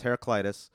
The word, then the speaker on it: Heraclitus: Last time we talked about Heraclitus, as you pronounce his name, incorrectly. It's Heraclitus.